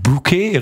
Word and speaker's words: Bokeh: Ein Bouquet, richtig. Wird genauso ausgesprochen, bloß ein bisschen anders geschrieben. – Nee, Bouquet. – Bouquet schreibt man ja fast so, wie man spricht. B-O-K-E-H.